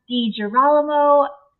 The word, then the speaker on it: DiGirolamo: I am here today with Dr. Carla DiGirolamo.